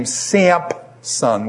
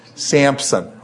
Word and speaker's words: Samson: You guys call him, how many of you pronounce this guy's name Sampson with a P in it? Samson, okay? We pronounce it with a P. Do you realize, is there any P in there? No.